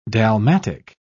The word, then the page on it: dalmatic: British English